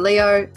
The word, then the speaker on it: Leov: or Tasha Leo. Just to point out, it's spelled L-E-O-V, just to mess with everyone's minds.